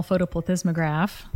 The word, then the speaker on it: photoplethysmograph: To give them a little credit, there's a little complexity there, but most people use something called a vaginal photoplethysmograph.